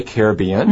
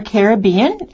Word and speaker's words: Caribbean: And as Lois mentioned earlier, that word Caribbean, or some people say Caribbean, can be said either way. – Right. It used to be more common to hear Caribbean as the first translation of the word.